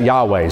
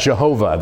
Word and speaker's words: Yahweh: It wasn't in chapter 1, but here in chapter 2, we have the name Yahweh, wherever you would see the capital L-O-R-D In Hebrew, it's the Tetragrammaton, YHWH, and we have pronounced that Yahweh. Some have pronounced that Jehovah.